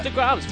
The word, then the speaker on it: DeGrom's: Jacob DeGrom's pitching.